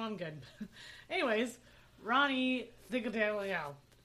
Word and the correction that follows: Threadgill: How is that even hard to pronounce? It's Threadgill.